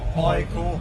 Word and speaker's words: AIK: You know, our, our, bass player was a, was a professional football player for AIK, and I still don't know how to say it. – AIK. – AIK. Okay, okay.